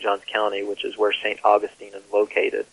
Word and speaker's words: St. Augustine: And I'm assuming from my memory that that's the way the Floridians pronounce it, St. Augustine, not Augustine, right? – Yeah, theologians pronounce it Augustine.